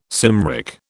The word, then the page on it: Cymric: British English